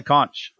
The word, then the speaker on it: conch: And Todd can play the conch. – Yeah. – Doctor can play the conch a little bit. – Excellent. – Conch or conch? What is the official pronunciation? Conch or conch? – Either are acceptable.